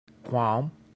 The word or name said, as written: quam